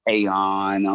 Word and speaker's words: Teyon: You know, Teyon. I'm like, I think the Y throws everybody off. It was just T-E-O-N.